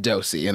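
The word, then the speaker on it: Dosie: It's spelled D-O-O-S-E, and we're supposed to say it Dosey, and I hate that. – No, that's a D-O-S-I-E.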